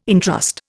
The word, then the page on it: entrust: Indian